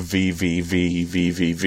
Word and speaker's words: VVVVVV: I mean, the 3DS version of VVVVVV is gone for good now. – I believe the proper pronunciation of that title is the letter V six times.